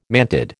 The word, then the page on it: mantid: American English